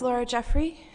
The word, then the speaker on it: Laura Jeffery: My name is Laura Jeffery. It's spelled L-A-U-R-A-J-E-F-F-E-R-Y.